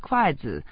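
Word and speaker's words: kuaizi (筷子): Chopsticks are traditionally placed in brides' dowries because chopsticks, in Chinese, is pronounced kuaizi, which sounds like get a son soon.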